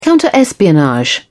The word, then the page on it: counter espionage: British English